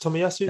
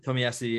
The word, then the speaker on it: Tomiyasu: Tomiyasu or something like that. Tomiyasu. – That's it. Tomiyasu, yeah, from Bologna.